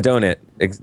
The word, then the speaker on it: Adonit: Okay, so it's Anowit. I think it's how you pronounce the name of the company. I'm probably getting it wrong. I got it right in my interview. – Adonit? Adonit? – Adonit. That's right. I left the D out.